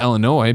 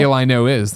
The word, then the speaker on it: Illinois: S at the end, but it's like Noy. – Yeah, exactly. Yeah. – Kind of cool. – Illinois.